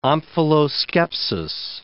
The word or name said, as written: omphaloskepsis